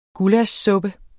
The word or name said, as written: gullaschsuppe